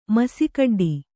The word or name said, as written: ಮಸಿಕಡ್ಡಿ